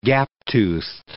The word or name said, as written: gap-toothed